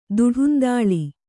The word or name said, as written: ದಿಡಂದಳೆ